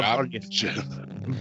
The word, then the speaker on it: Barnyashev: You know, Rusev, you know, being as big of a name as he was, you know, if he's on the card as Miroslav Barnyavnyshev, geez, I apologize for butchering that name. Barnyashev? Miroslav Barnyashev.